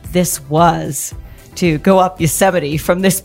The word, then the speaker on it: Yosemite: Is that what you guys Yosemite National Park. – Yes. I'm Canadian. I don't know how to pronounce anything. – That's okay. It's Yosemite National Park and the mountain's called El Capitan.